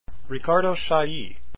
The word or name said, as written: Chailly